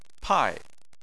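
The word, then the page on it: pai: tone 4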